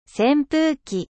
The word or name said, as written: 扇風機